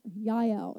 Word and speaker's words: Yael: So I'm going to talk about a little bit today about Deborah and Yael is actually how you say her name.